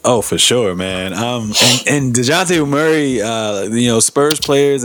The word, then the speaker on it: Dejounte: and y'all relying on a young, how do you say, DeJounte or DeJounte? Yeah, DeJounte Murray.